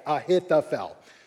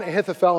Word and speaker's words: Ahithophel: I had to watch this video like five times, but I think I got it. Ahithophel. Amnon, Ahithophel, and Absalom.